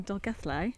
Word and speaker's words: Dolgellau: So yes, we are in Dolgellau. – Yeah, brilliant. – Did I pronounce it right? – Well done.